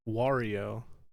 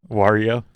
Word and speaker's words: Wario: Wario? Like Mario? – That feels like work.